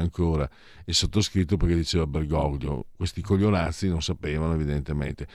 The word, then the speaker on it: Bergoglio: Perché quando ho visto Milei abbracciare con enfasi estatica eh, Bergoglio, si dice Bergoglio, non Bergoglio, perché lui è argentino, quindi bisogna pronunciarlo in castigliano,